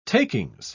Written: takings

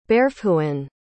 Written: bairrfhoinn

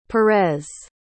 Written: Perez

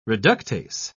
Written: reductase

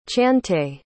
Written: chantay

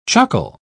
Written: chuckle

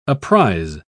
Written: apprize

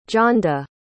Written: johnda

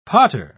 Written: potter

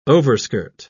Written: overskirt

